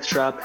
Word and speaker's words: Schrupp: My Twitter is Kenneth Schrupp. Last name is spelled S-C-H-R-U-P-P.